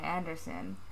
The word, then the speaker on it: Andersson: My name is Linnea Anderson.